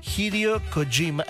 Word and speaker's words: Hideo Kojima: porque no, fíjense que será el protagonista de Visint, el nuevo videojuego de Hideo Kojima. Así se pronuncia.